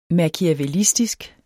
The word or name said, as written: machiavellistisk